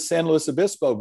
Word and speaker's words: San Luis Obispo: And then I said, I've never been to San Luis Obispo before. And she said, you pronounced it right.